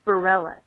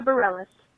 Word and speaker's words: Bareilles: You're trying to decide how to say the name of the Courage singer, Sarah, and her last name is Bareilles, as I understand it. So, Sarah Bareilles, singer of Courage.